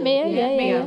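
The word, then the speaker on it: Meo: It's M-E-O. Am I saying that correctly? – Yeah, Mio. – Like Tony Mio.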